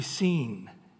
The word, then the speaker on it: Sin: And then if you say, how do you pronounce S-I-N, it's actually seen is the way they pronounce it.